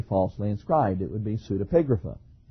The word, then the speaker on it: Pseudepigrapha: Pseudepigrapha.